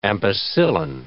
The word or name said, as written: ampicillin